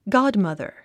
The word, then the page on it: godmother: gɑ́dmʌ̀ðə r; ゴッドマザー